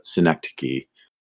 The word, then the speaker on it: synecdoche: And I've heard it pronounced synecdoche, but I could be mistaken. – It is synecdoche.